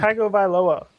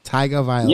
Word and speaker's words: Tagovailoa: They had a nice win, and it didn't matter because if you didn't see it, Tua Tagovailoa. What, how is he his name? Tua. I know. – Tagovailoa. – Tagovailoa. – Yeah, you keep saying it right other times.